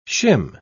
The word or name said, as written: shim